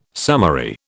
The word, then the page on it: summary: British English